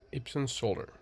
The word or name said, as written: Ipsun Solar